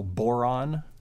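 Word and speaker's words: bodhrán: I've heard it pronounced very similarly to the mineral boron.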